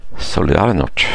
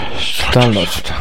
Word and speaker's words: Solidarność: Den klassiska fackförbundet, hur uttalar man det här? Solidarność. – Solidarność. – Solidarność. – Ja.